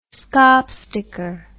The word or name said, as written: skaapsteker